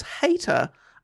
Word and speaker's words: Hayter: I think I was reading Hatter, but it's clearly Hayter. H-A-Y-T-E-R.